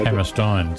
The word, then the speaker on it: Hammerstein: And the Americans say Steen. Actually, they pronounce it as E-I in German. It's pronounced I-N. – So Hammerstein.